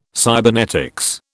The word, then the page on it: cybernetics: British English